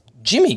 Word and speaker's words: Yimi: Yimi Garcia, or rather, I looked up the pronunciation guy. I didn't realize this. It's Jimmy Garcia.